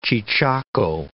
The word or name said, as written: cheechako